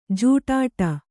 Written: ಜಿಡ್ಡು

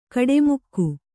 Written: ಕಾಡುಮಿಗ

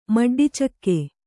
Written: ಮಡ್ಡಿಚಕ್ಕೆ

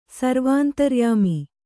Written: ಸರ್ವಾಂತರ್ಯಾಮಿ

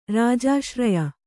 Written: ರಾಜಾಶ್ರಯ

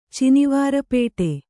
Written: ಚಿನಿವಾರಪೇಟೆ

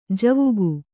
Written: ಜಾವಕ್

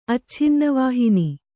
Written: ಅಚ್ಛಿನ್ನವಾಹಿನಿ

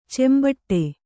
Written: ಚೆಂಬಟ್ಟೆ

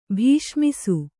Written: ಭೀಷ್ಮಿಸು

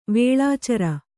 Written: ವೇಳಾಚರ

